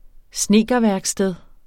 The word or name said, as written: snedkerværksted